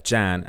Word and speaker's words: Jan: So the film, 1972, Mechanic, starring Charles Bronson, Jan, as it's pronounced in America.